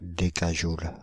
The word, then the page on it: décajoule: Paris; [de.ka.ʒul]; France (Île-de-France); /de.ka.ʒul/